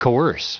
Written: coerce

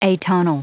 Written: atonal

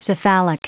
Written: cephalic

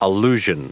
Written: allusion

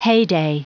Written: heyday

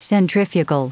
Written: centrifugal

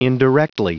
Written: indirectly

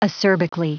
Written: acerbically

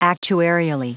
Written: actuarially

